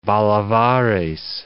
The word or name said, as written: bolivares